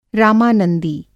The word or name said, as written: रामानंदी